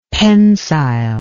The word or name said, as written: pensile